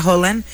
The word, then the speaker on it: Haaland: Haaland, que dicen que se pronuncia Haaland,